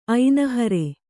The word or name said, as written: ಐನಹರೆ